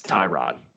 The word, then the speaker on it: Tyrod: How do you pronounce the former Buffalo Bills quarterback Taylor, his first name? – Tyrod. – It's Tyrod. Tyrod. Tyrod. He tried to tell us differently, too.